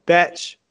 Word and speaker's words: betch: It's betch, like B-E-T-C-H, betch.